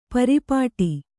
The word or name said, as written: ಪರ್ಪಟಿ